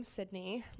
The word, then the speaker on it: Cydnee: So Instagram is going to be just my name, Cydnee.